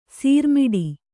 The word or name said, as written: ಸೋರುಮುಡಿ